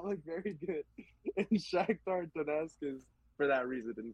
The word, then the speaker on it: Shakhtar Donetsk: And then for third, Europa League, I got Shakhtar Donetsk. – You know, you said it right. – I want to say, yeah, Shakhtar Donetsk.